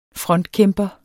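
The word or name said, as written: frontkæmpere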